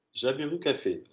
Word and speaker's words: Jabiru Café: D'abord, est-ce que euh, j'ai bien prononcé le nom Jabiru Café, tu as bien prononcé, oui, exactement.